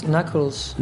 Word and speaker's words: knuckles: Okay, my word is knuckles. – Knuckles. – So, K-N-U-C-K-L-E-S. – Yeah, tricky one because that K is weird at the beginning, isn't it? – Yeah, yeah, yeah.